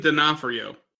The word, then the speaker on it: D'Onofrio: It's Vincent D'Onofrio. – D'Onofrio. – Yeah. – The apostrophe apparently is silent. I didn't know apostrophes made a sound, but they are silent.